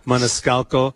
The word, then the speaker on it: Maniscalco: Maniscalco.